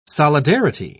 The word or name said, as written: solidarity